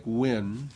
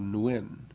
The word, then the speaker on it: Nguyen: Nguyen sounds like Win. The potential supporters are instructed. So Nguyen.